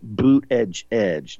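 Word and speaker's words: Buttigieg: Pete Boot Edge Edge. That's how he pronounces it.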